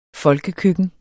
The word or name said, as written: folkekøkken